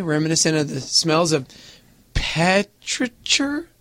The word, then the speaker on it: petrichor: Yeah, so petrichor is the way we pronounce petrichor.